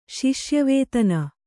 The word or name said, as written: ಶಿಷ್ಯವೇತನ